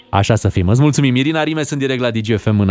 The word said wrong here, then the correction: Irina Rimes: Pronunță-mă corect, mă. – Cum? Irina Rimes.